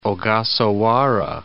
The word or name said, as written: Ogasawara Islands